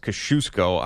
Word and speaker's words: Kosciuszko: Some people say it's pronounced Kosciuszko. I like to say Kosciuszko.